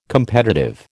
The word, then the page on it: competitive: American English